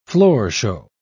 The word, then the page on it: floorshow: British English